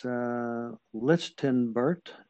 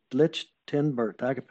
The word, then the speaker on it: Lichtenbert: But to make sure you spell it correctly, it's Lichtenbert, L-I-T-C-H. – L-I-C-H-T. – L-I-C-H. – Mm-hmm. – T-E-N, Tenbert, Lichtenbert.